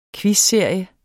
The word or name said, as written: quizserie